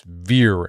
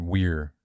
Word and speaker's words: vir: It's vir. I mean, you can pronounce it weir too.